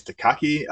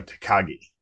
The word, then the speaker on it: Takagi: So um, in in Die Hard, Hans Gruber, the main, it's I think it's Takagi. Uh, I think I said that right. No, Takagi.